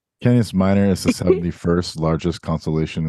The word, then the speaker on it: Canis Minor: The constellation named Canis Minor is pronounced Canis Minor.